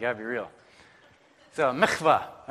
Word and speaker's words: Mikvah: Mikvah. Right? You got some Hebrew in today. You got to like say it like really Hebrew though. Mikvah.